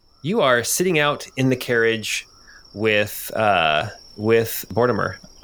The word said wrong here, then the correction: Bortimus: Oh no, I can't even say his name right. – Bortimus.